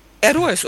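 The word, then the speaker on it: Héroes: Und jetzt sag uns noch, warum du dir das Lied gewünscht hast, Entre dos Tierras von Hieros del Silencio. – Heroes, oder? – Sagt du, ja, Heroes ist besser.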